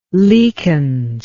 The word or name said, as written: leakance